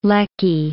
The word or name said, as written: Lecky